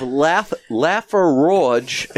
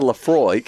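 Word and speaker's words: Laphroaig: We have Laferoge instead of Lafroig.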